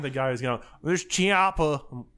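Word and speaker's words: Chiappa: I think the H is silent, guys. Come on, let's be real. It's Chiapa.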